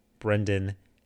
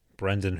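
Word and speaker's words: Brenden: you can find me on Instagram at Brendan H. And I will spell that for you because, as has happened many times in my life, people butcher the name Brendan. So that's B-R-E-N-D-E-N.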